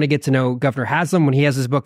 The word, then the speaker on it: Haslam: I've heard our mutual friend, Scott Sauls, told a beautiful story about how you and Governor Haslam, am I saying his name right? – Right.